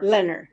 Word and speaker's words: Lenher: Now we have someone whose name I'm not sure how to pronounce. L-E-N-H-E-R? – Leonard.